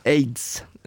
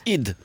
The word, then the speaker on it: Eid: AIDS. – Nej, men. – Det uttalas id.